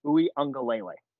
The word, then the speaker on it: Uiagalelei: And that's our Uiagalelei. That's how you pronounce it.